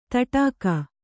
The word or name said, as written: ದಡಿಕೆ